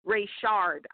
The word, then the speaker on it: Rayshard: Mr. Rayshon Brooks, am I saying his name correctly? I want to make sure to say that. Rayshard, I apologize.